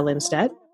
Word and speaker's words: Linsteadt: This is kind of exciting because not only am I excited to bring on our guest, Julia Linsteadt.